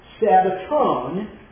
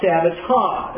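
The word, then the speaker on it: sabbaton (σαββάτων): The genitive plural, sabbaton, not sabbata. That's an omega and a nu on the end. It's pronounced own.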